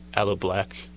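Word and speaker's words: Aloe Blacc: The next song I'm going to go to is I Need a Dollar by Aloe Vera, so that's how you say it. Aloe Black, from the album Good Things.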